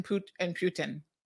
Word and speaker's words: Putin: But yes, the way that I'm supposed to remember it is it's very close to the Spanish word puta. Puta and Putin. So if I remember puta, the Putin is a puta, I should be able to pronounce it correctly.